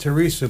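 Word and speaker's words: Thérèse: And it's interesting, it's Saint Teresa, but it's spelled T-H-E-R-E-S-E, but that's the way it is.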